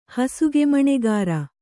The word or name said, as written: ಹಸುಗೆಮಣೆಗಾರ